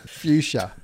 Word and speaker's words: fuchsia: Fuchsia. – I know. – Like F-U-S-C-H-I-A. – That's a word. I've always... – Focaccia. Sandwich. – I didn't know. How did you say it? – Fuchsia. – Fuchsia?